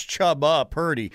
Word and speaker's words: Chubba Purdy: I thought there was only going to be one Chuba. It's Chuba Hubbard. It's Chubba Purdy.